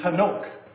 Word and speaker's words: Hanok: If you were to spell it out maybe a little bit more in Hebrew, it'd be Hanok. And so you get that H sound at the beginning.